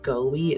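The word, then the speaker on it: Goewey: I think the last name is pronounced Goey. It's G-O-E-W-E-Y.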